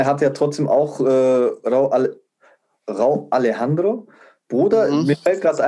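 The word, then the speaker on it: Rauw: Rau Alejandro. – Rau, das W ist einfach stumm, still. – Ja, ist einfach R-A-U. Ja. – Rau.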